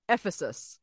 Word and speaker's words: Ephesus: The action takes place in Uffizi's. I said that wrong, didn't I? I knew I was going to say it wrong. How do you? – I mean, Ephesus. – Ephesus. I put the wrong emphasis.